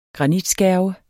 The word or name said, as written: granitskærve